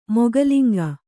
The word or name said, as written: ಮೊಗಲಿಂಗ